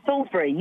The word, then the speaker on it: Salisbury: Yes, Salisbury.